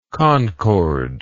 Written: concordal

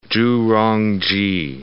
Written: Zhu Rongji

